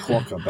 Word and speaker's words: quokka: It's called a quokka. Bear me a second. – It's called semen. – That's spelled Q-U-O-K-K-A.